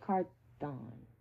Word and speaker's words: Carthon: One real interesting situation is that my Tennessee Titans just got a new GM from the 49ers, Rand Carthon. Yeah, that's how you pronounce it.